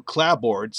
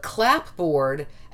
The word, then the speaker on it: clapboard: Oh, I was going to say, but now of late, there's a thing called spelling pronunciation, which is when you pronounce a word the way it's spelled. And for some reason, we haven't changed cupboard to cupboard, as you pointed out, but clapboard, as it's spelled, is now considered an acceptable pronunciation.